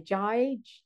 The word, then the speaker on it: Jai: And I also read something about, am I pronouncing this right? J-A-I, Jai, Jai? – Yes.